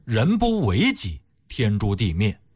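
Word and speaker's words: 人不为己，天诛地灭: 哪还有什么情分，人不为己天诛地灭。 – 其实您仔细琢磨琢磨，他这么解释的话也不太通啊，你这么提倡自私的句子为什么千古流传呢？哎，其实啊这句话应该读成是人不为己天诛地灭。为就是修为的为啊，人呢如果不修身养性不修为自己的话，天地都看不下去，是这么一个意思。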